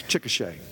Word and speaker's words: Chickasha: Chick- Chickasha. Did I say that right? Yeah. That place, Chickasha.